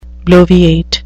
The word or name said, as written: bloviate